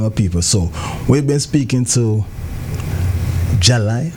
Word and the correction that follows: Jalia: if I'm pronouncing the word right. – Jalia. – Jalia. – Correct.